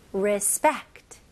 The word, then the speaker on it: respect: 자, 발음은요, RE로 시작하지만 발음은 I처럼 발음하시면 좋아요. 그래서 respect 이렇게 하면 힘을 너무 줘야 되잖아요. 그래서 re, 그 다음에 spect. S-P-E-C-T는 한 음절로 발음하셔야 됩니다. 해볼게요. respect, respect, respect. 네, 강세가 어디 들어가죠? 네, spect에 들어갑니다. 그래서 re는 그렇게 중요하지 않아서 짧게 해주셔도 돼요.